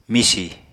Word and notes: misi: Apatani

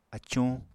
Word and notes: acuñ: Apatani

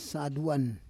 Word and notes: saduan: Roon

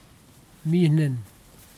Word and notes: minin: kwamera

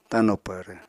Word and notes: tano pvrv: apatani

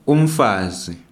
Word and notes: umfazi: ndebele

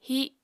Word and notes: híh: apatani

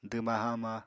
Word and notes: dwma-haama: apatani

